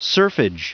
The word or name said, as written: serfage